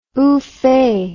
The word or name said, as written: oofay